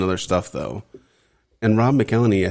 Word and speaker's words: McElhenney: So October 1st, 2008, Caitlin Olsen weds Rob McElhenney.